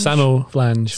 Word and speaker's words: samoflange: Salmon flange? – Samo flange. – Samo. – Yes. – Can you spell it for us? – Yeah. I think it would be S-A-M-O, possibly a U-F-L-A-N-G-E.